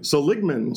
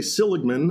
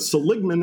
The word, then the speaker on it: Seligman: Well, I stopped by, and I need to say I was in, and I'll probably mispronounce the name, Seligman. – Seligman. – See, in the Northeast, we'd say Seligman, right. But Seligman, Arizona.